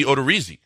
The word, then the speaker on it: Odorizzi: And Odorizzi should be Odorizzi because it's an I and which is pronounced E.